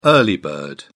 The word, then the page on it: early bird: British English